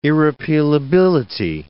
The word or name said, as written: irrepealability